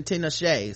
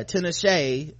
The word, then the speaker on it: Tinashe: Okay, it does look like, according to this, is pronunciation Tinashe. So you're right, Bossy. My bad. Tinashe.